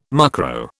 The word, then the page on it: mucro: British English